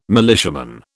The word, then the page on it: militiaman: British English